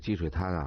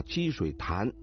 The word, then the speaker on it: 积水潭: 说这个积水滩啊，这我又得啊，它这个正确的读音应该是潭啊，积水潭，但是北京人啊一般的都说积水滩啊。